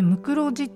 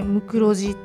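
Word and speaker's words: 無患子: で「むくろジ」ってこう漢字で書くと「ない」「患者の患」「子供の子」と書いて「むくろジ」っていうふうに読むんですよね。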